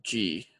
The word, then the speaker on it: Xi: Is that how you pronounce his name? Xi? – Xi. Oh, Xi. Like, yeah, Xi. X-I.